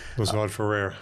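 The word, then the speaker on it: Lazard Frères: I think that, I don't know how to pronounce the company, Lazard Fares. – Yes. Lazard Ferrer.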